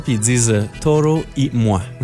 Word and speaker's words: Toro y Moi: Toro y moi. Ah, c'est pas super. – Toro, faut y aller avec un accent espagnol. – Toro y moi.